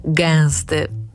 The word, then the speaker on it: gęsty: I ten właśnie korek czujemy, gdy mówimy gęsty.